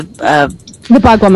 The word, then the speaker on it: Bagua: It's the Bagua map, yeah.